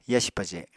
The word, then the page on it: yasi paje: apatani